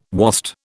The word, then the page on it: wast: British English